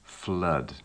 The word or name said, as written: flood